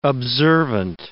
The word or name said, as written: observant